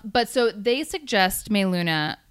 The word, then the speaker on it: Meluna: It might be pronounced Meluna, but I've been calling it Meluna. Meluna. – How do you know it's not Melina? – Well, great point. It's capital M, little E, capital L, U-N-A.